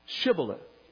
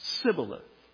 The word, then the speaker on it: Shibboleth: And if he said, nay, then said they unto him, say now, Shibboleth. And they said, Sibboleth. Another one of those great verses in the Old Testament. They had speech impediments. Apparently, their pronunciation of the ancient language was not as clear. They could not say the SH sound.